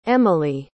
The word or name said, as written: emelie